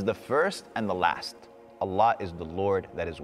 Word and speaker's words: Allah: It is pronounced A-double-L, exhale with love, Allah.